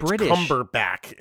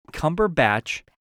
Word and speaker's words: Cumberbatch: Benedict Cumberbatch. – It's Cumberbatch.